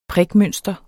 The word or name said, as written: prikmønster